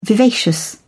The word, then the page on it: vivacious: British English